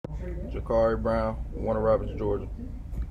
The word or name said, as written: Jaquari Brown